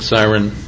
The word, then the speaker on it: Syron: It's Hopson, H-O-P as in Paul, S-O-N. – Dick Siren, representing myself. Last name is S-Y-R-O-N. – Tom Green, spelled G-R-E-E-N, from Sidley Austin.